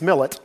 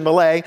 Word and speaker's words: Millet: And if you look at this, now the Cleveland pronunciation of this is Jean Francis Millet, I know, but we'll go with the French version, Millet.